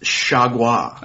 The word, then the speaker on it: Chargois: I'm going to tell you guys how it's spelled Chargois, Chargois. It's pronounced Chagua.